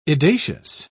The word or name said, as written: edacious